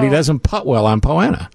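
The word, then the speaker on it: Poa annua: It's Poana. – Poana. – Yeah. – Yeah, Poana, yeah. – But he doesn't putt well on Poana. – Sounds like piranha.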